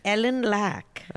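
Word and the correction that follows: Eleniak: I think that's how you pronounce it. – Ellen Yack. – Ellen Yack, sorry, whatever.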